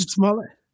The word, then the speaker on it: Jussie Smollett: Jussie Smollett.